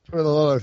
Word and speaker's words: Toilolo: Colt McCoy is a backup quarterback, Levine Toilolo. I can't pronounce anything today. – Toilolo.